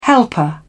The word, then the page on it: helper: British English